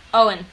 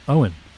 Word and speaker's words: Eoin: how would you pronounce the name, which is E-O-I-N? – Owen. – Owen. Cool. Owen Maron. – It's Irish.